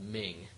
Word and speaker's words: Mhing: there's a great card game called Ming. – Right. – It's spelled M-H-I-N-G.